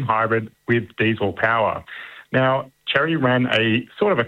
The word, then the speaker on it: Chery: Well, next we're looking at the new, is it pronounced Chery? Chery, yeah.